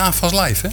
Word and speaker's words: AFAS Live: Madness treedt trouwens volgende maand op in AFAS Live, hè? – Ja, ik zag het. Of nee, zeg ik het goed? Ja, 7 juli.